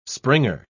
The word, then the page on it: springer: British English